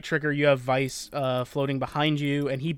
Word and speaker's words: Weiss: It's, well, it's spelled Weiss, pronounced Vice with the new voice work.